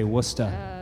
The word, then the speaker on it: Worcester: I believe around here they say Worcester.